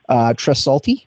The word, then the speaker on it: Tresaulti: I'm going to just butcher that last word. Tresaulti, is that right? – Yeah.